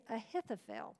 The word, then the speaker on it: Ahithophel: it is Ahithophel. Now, I am not familiar, Ahithophel, I know no one by that name, but that is how you say it because I did look it up.